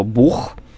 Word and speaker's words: бог: Благодаря Киеву, который в 17 веке был центром учености, а значит, так сказать, законодателем нормы, мы, например, произносим слово «бог», именно так, как произносим его сейчас. Хотя, конечно, мы с вами понимаем, что для русского языка это не характерное произношение. То есть это все-таки более киевское. Южное, да, южное более такое. Киевское, более южное произношение.